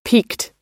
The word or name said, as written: peaked